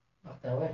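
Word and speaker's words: Mardawaih: Dikatakan oleh Ibnu Mardaweh, Mardaweh berarti cara bacanya seperti si bawah.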